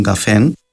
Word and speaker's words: καφέν: Στα κυπριακά το καφέ με δυο κάπα στην αρχή της λέξης είναι ο τόπος συναισθίασης όπου σερβίρονται μεταξύ άλλων και καφεϊνούχα ροφήματα και ξεχωρίζει φωνητικά από τον καφέ, δηλαδή την αιτιατική της λέξης ο καφές που έχει τελικό νι τον καφέν, αλλά διαφοροποιείται και από το καφέ το χρώμα που έχει τελικό νι, καφέ. Στην κοινή και τα τρία προφέρονται το ίδιο, καφέ.